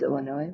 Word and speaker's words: Illinois: It is Illinois with a silent S.